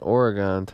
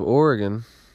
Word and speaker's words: Oregon: Now, Oregonians from the state of Oregon will quickly point out that it's pronounced Oregon, and that you're a fool, and they'll talk down to you, and they'll say, "It's not Oregon, it's Oregon, Oregon."